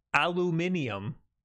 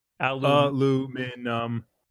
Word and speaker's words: aluminium: This is for your phonetically spelling aluminium. – Aluminium accent. That's just not correct. There's an extra syllable in there. They throw an I at the end. – Aluminum.